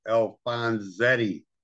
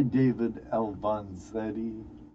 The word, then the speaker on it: Alfonzetti: How do you pronounce it? No, not that David. The other David, Alfonso Reddy. Alfonzetti. – Hi, David Alfonzetti. – Yeah, there's no R.